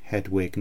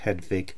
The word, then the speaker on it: Hedwig: For example, Walter, not Walter, Hedwig, not Hedvig, so I will be following this convention in my pronunciations in this episode.